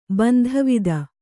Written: ಬಂಧವಿದ

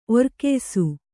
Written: ಒರ್ಕೆಯ್ಸು

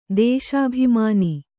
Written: ದೇಶಾಭಿಮಾನಿ

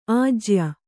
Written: ಆಜ್ಯ

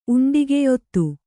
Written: ಉಂಡಿಗೆಯೊತ್ತು

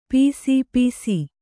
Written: ಪಿಸಿಪಿಸಿ

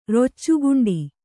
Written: ರೊಚ್ಚೆಗುಂಡಿ

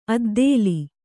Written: ಅದ್ದೇಲಿ

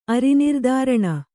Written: ಅರಿನಿರ್ದಾರಣ

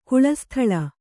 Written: ಕುಳಸ್ಥಳ

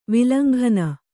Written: ವಿಲಂಘನ

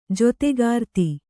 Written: ಜೊತೆಗಾರ್ತಿ

